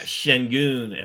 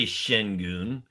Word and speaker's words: Sengun: Yeah, I say Sengun. I feel like that's how other people say it.